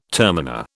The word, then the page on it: terminer: British English